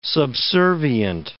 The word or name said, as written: subservient